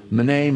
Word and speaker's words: mene: And it's these words that most people pronounce, meeny, meeny, tekel, upharsin. It's actually mene, mene, tekel, pharsin.